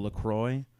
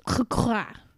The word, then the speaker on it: LaCroix: And could you say Melly is like a tropical LaCroix, but Melissa is polar seltzer water? – I think it's pronounced LaCroix.